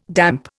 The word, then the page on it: damp: Indian